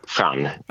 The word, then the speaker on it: Jean: Uttalar jag ditt namn rätt? Ska du uttalas franskt? – Nej, utan jag kallar mig själv Jean.